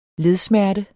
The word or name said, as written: ledsmerte